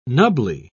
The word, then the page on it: nubbly: British English